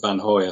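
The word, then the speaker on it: Vanhoye: I suspect his name has been pronounced all sorts of ways, and we'll, as you said, we'll stick with Van Hoy, I think, for this Flemish name, despite being a French scholar.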